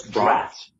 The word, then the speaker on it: draught: That's draft. – Draft. – Draft. It's draft horse. It's a draft.